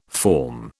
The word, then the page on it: form: British English